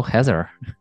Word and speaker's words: Heather: Heather, it's like weather.